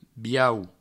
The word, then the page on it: biau: Provençal